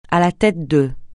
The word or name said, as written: à la tête de